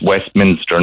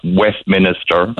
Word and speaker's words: Westminster: That's another word that's mispronounced. It's Westminster, not Westminster.